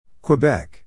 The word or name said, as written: Quebec